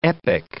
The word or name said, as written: epic